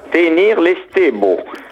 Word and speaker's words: Tener l'Esteva: Voilà, alors ça s'écrit Tener l'Esteva et ça se prononce en occitan Tenir l'Estebo.